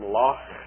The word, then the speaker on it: loch: When he speaks about lake, he uses the term loch, and so he gets that particular pronunciation of ch that is very much like the German ch.